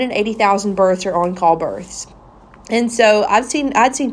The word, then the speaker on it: en caul: And I said at one point, I remember sitting on the birthing ball and I said, I think on-call births are so cool. And before I go any further, let me explain what an on-call birth is. On-call is a French word. It's spelled E-N C-A-U-L, on call.